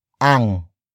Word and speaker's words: ang: The next word is ang, which is spelled as A-N-G.